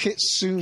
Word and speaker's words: Kitsune: It's um, it's by um, it's, it's Kitsune Kitsune Pronoun- books. You, you pronounce the e.